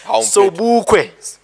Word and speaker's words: Sobukwe: Sobukwe. – He's a Sibukwe. What did I say? What the fuck did I say? – Sobukwe. – Sibukwe. King. – Sobukwe.